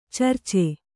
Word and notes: ಚಿರಚ: Kannada

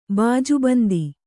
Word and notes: ಬಾಜುಬಂದು: bāju bandu

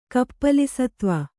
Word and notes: ಕಪ್ಪಲಿಸತ್ವ: kannada

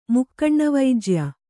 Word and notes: ಮುಕ್ಕಣ್ಣವೈಜ್ಯ: Kannada

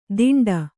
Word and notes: ದಿಂಡ: diṇḍa

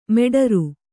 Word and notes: ಮೆಡರು: meḍaru

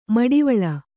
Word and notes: ಮಿಡಿವಿಲ್: miḍivil; Kannada

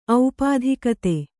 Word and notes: ಔಪಾಧಿಕತೆ: aupādhikate

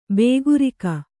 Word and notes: ಬೇಗುರಿಕ: bēgurika

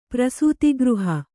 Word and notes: ಪ್ರಸೂತಿಗೃಹ: kannada

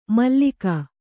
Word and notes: ಮಾಲಕ: kannada

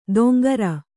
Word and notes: ದೊಂಗರ: doŋgara

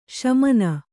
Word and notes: ಶಾಮನ: śamama

